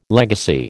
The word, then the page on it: legacy: American English